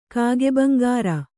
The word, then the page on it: ಕಾಗೆಭಂಗಾರ: kāge bhaŋgāra